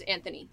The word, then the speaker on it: Anthony: I guess if you're British and proper, that's how you pronounce Anthony. – Anthony.